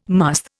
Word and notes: musth: Indian

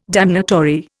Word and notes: damnatory: Indian

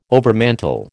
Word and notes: overmantel: American English

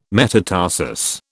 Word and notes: metatarsus: British English

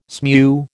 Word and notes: smew: American English

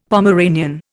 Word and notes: Pomeranian: Indian

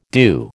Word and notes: à deux: American English

